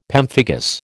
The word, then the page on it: pemphigus: American English